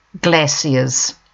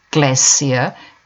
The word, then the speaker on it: glacier: Glaciers, rivers of ice. Note that we pronounce the C as an S, glacier, not SH, not glacier. That's American English.